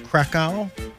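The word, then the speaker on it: Kraków: How about what happened in the Polish city of, I think it's pronounced Krakow, K-R-A-K-O-W, close enough? – Good enough.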